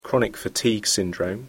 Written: chronic fatigue syndrome

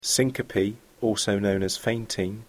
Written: syncope

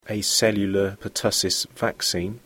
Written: Acellular pertussis (aP) vaccine

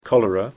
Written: cholera

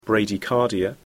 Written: bradycardia

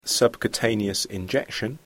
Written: subcutaneous injection

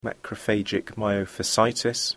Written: macrophagic myofasciitis

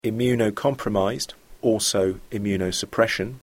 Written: immunocomprised

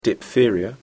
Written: diphtheria

